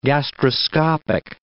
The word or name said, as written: gastroscopic